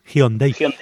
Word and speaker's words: Hyundai: Hyundai. ¿No? Sí, la pronunciación coreana es Hyundai.